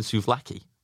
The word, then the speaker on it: souvlaki: I think I'm saying souvlaki. – It is souv, S-O-U-V-L-A-K-I. Souvlaki.